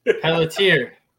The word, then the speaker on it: Pelletier: Pelletier. – Pelletier. – As the French call it, Pelletier, yeah.